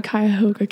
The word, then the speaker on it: Cuyahoga: And basically what I'm doing is I'm creating information for all the major counties in Ohio, Lucas County to, how do you say it? – Cuyahoga County, yeah.